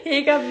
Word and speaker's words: Hega: Wie Jäger, nur mit H? Nee. So, so wird sie, so wird ausgesprochen. Heger wurde, glaube ich, e. – H-E-G-A geschrieben. Heger.